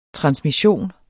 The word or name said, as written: transmission